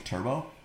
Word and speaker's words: Turbaux: And his name is spelled T-U-R-B-A-U-X. – Yeah. – Turbo.